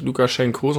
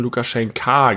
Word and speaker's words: Lukaschenka: Ja, aber du hast korrekterweise unsere Folge nicht Lukaschenko, sondern Lukaschenka genannt. Lukaschenko ist, glaube ich, die russische Aussprache oder so. Ja, heißt also eigentlich Lukaschenka.